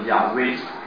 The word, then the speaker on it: 亚威: 你知道耶和华这个字应该叫做亚威，亚威也不是说他的名字姓亚叫威，亚威的意思就是说我是啊，用这个希伯来语讲，我是这个意思，所以这是神启示的他自己的一个名。